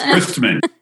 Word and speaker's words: Christman: I mean, it sounds so holier that way. Man of Christ. But yes, Christman.